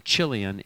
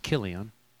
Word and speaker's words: Chilion: And by the way, when you see a CH in English, it's almost always pronounced K in the Bible. Rather than Chilion, it's Chilion. And in Hebrew, they rhyme.